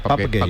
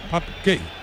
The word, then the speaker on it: Pape Gueye: le pregunté y me dijo la pronunciación correcta, que es Pap Gueye, ¿no? – Pap Gueye.